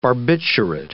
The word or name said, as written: barbiturate